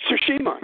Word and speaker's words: Tsushima: Tsushima that destroyed it? – Tsushima, yeah.